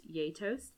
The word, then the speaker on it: gjetost: It's spelled like G-J-E-T-O-S-T. – Okay. – It's pronounced Yay Toast.